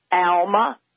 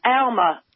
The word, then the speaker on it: Alma: South, it's Alma. – Alma. – How do you spell it? – Alma. Y'all got me saying Alma. – Well, wait, how do you spell it? – A-L-M-A, same way. – And you pronounce it Alma? – Alma. Like Alabama.